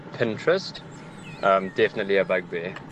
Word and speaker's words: Pinterest: For me, it definitely has to be Pinterest when people pronounce it Pininterest. The word reads Pinterest.